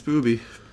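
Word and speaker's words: Booby (ブビィ): I think that's pronounced... Booby. It's without a doubt. It's Booby.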